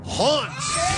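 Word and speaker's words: Hontz: Hunts. Might be pronounced Hunts. H-O-N-T-Z. H-O-N-T-Z.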